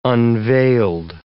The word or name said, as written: unveiled